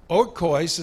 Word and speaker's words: oikos: Oikos is spelled O-I-K-O-S, oikos.